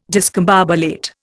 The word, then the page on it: discombobulate: Indian